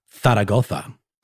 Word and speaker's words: Zaragoza: This story of spiritual shenanigans begins in September of 1934 in a city in Spain called, and I've been waiting a long time to be able to say this properly, Zaragoza. – Right, with the Castilian Spanish that's spelled with two Z's.